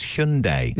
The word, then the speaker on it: Hyundai: And also, the Hyundai thing, they've been banging on about Hyundais for years, and now, very smugly, they're telling people, no, it's Hyundai.